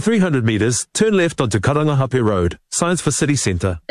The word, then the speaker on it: Karangahape: That was TVNZ's Māori Issues reporter Te Aniwa Huri Hanganui driving around Auckland and unimpressed with the navigation voice on the Google Maps app mispronouncing Karangahape Road and other places with Māori names.